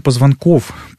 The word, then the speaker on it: позвонков: Копчик — 5 маленьких позвонков, которые берут на себя основной удар при падении. Так вот, перелом позвонков, правильно говорить, позвонков, не позвоночника, так же, как и межпозвонковая грыжа, два позвонка вместе, связанные с диском и связками, с суставами, называются позвоночно-двигательный сегмент, ПДС.